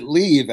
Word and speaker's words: Liv: Yes, she pronounces it Leave, actually. – Oh, thank you. Leave Cummins.